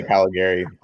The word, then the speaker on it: Caligari: And that would be the Italian name to say it. I'm third generation. We say Caligari.